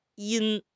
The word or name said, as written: yin1